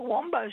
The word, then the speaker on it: Wambo: Other one, we have a Wombo Street, which is spelt Wambo Street.